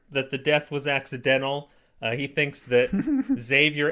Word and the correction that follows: Xavier: and I'm apparently saying it wrong, it's ex-Xavier.